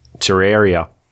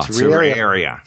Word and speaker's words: Terraria: Terraria. – Oh, Terraria.